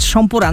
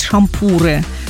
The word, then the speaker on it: шампуры: Ну, хочется, правда-то легче сказать «шампура», но неправильно. А правильно сказать «шампуры». Да, вот на «у» всегда «шампуры», о шампурах, шампуром, шамп...